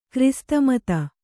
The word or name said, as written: ಕ್ರೈಸ್ತಮತ